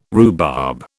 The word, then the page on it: rhubarb: British English